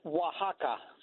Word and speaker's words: Oaxaca: It's pronounced Oaxaca, or if they're talking about the individual, they would be Oaxacan.